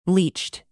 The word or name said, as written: bleached